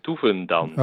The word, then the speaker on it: Dovendans: How would you say that in Dutch? – Okay, I will. The first name pronunciation was perfect. – Then. All right, I'll take it. – And the surname is Dovendans.